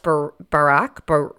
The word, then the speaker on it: Braque: To meet up with George Baraque, B-R-A-Q-U-E. I never say his name right because I don't. – B-R-A-Q-U-E. – Oh. French. He's French. – Oh, yeah. I don't know. – Baraque.